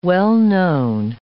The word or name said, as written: well-known